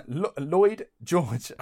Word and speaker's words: Lloyd George: Now, towards the end of the war in 1917, Lord George... Lloyd George... – Say that again. – Said that wrong. – Lloyd George. – I mean, he is Welsh, so it could be right.